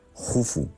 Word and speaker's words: Jufu: se escribe Keops, pero se pronuncia Jufu, y casi nadie llama Jufu, porque Herodoto nos lo tradujo del griego y bueno, pues ahí se ha quedado, ¿no?